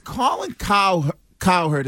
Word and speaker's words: Colin Cowherd: It's Colin Cowherd. Is that how you say his name? – Nailed it. Yep.